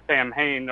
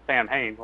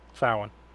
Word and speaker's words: Samhain: That's how I always thought at first, because the first thing I think I was familiar with the word Samhain or whatever was probably Halloween 2. And Donald Pleasance, like I said, pronounces it Samhain, like it looks.